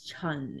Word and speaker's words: Chan: Like very like wildly known in American as Chan. – Yeah. Chan. – Is Chun in Cantonese. – Chun. Like even Chan is like C-H-A-N or C-H-E-N.